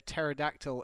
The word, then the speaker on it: pterodactyl: Why can't you hear a pterodactyl in the bathroom? – Why, handsome? – Because the P is silent.